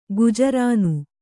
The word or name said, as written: ಗುಜರಾನು